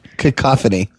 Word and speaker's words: cacophony: Cacophagon? How do you say that word? – Cacophony.